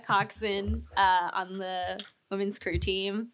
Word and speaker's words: coxswain: It's spelled coxswain, but it's pronounced coxswain, which isn't any better.